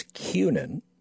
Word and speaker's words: Kunin: And people would look at me and I said, because my last name is Kunin even though it's spelled with a K, the phonetically you have the word Q, the letter Q there, and so it would be L M N O P Q N.